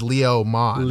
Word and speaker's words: Leomon: It's spelled suspiciously like lemon, but it is Leomon.